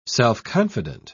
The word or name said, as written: self-confident